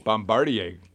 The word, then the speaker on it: Bombardier: Bombardier. – Bombardier.